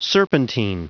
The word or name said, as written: serpentine